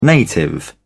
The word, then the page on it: native: British English